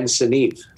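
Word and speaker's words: Sinnaeve: But I'm going to say Ken Sinavy. – Wow, that's the closest. I didn't know how to pronounce it for many years. It's actually Ken Sinavy.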